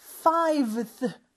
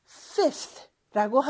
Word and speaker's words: fifth: 자, 다섯 번째는 five인데 five가 요 V 발음이 어려워요. fiveth. 오, 너무 어렵죠? 그래서 요거를 비슷한 발음의 F로 바꿔서 fifth라고 하는 거죠.